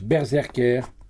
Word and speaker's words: BRZRKR: Le titre se prononce Berserker.